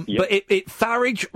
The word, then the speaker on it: Farage: But Farage rhymes with garage.